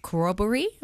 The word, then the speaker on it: corroboree: 800 critically endangered northern, now corroboree, am I saying that correctly? – Yep.